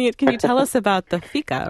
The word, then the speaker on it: fika: You had an interesting part of the cookbook where you talked about, I guess it's pronounced fika in Sweden, yep.